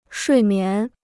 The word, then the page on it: 睡眠: shuì mián